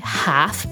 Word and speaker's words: half: Oppure half board, mezza pensione, half board. E la pronuncia qui è importante perché nonostante metà si scriva half, la L non si sente affatto. Half è la pronuncia corretta.